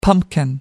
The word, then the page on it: pumpkin: American English